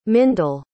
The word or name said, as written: mindel